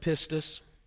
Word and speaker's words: pistis: The word that's found in the original language of the New Testament, it's pronounced pistis. It's translated faith.